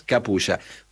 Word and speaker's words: Capuy: todas letras, Capulla. Doctor Capulla, C-A-P-U-Y. ¿Sabes que en Japón hay muchos nombres que terminan en Uya, Ajá. – igual, se escribe igual con Y? – Sí.